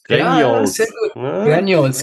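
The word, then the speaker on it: Grengiols: das musst du mir helfen, wie man das ausspricht. Ich habe es wahrscheinlich immer falsch ausgesprochen. Grenjolz? Grenjolz? Grenjolz. Grenjols. Ja, ah. genau, ja.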